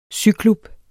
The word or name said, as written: syklub